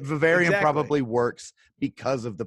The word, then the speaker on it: Vivarium: Again, Vivarium, like, did I pronounce it right? Vivarium? – Yeah, Vivarium. Mm-hmm.